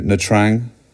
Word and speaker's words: Nha Trang: Yeah, we're heading off to Nha Trang.